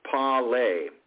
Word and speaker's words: pale: And I want you to know that it's not to be confused with that word from the Pirates of the Caribbean, arg, of parley. Here again, that it's just pronounced pale.